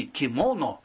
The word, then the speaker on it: кимоно: Кстати, актрисы студии Левинского рассказывали мне, что облачение актрисы перед спектаклем в кимоно или кимоно, как это правильно произносится по-японски, тоже представляет собой особый ритуал.